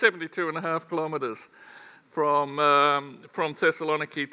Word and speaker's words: Thessaloniki: And then we were talking about Thessalonica, and Thessalonica became Saloniki, and now it's being pronounced again as Thessaloniki. So the names sort of shift and change over time.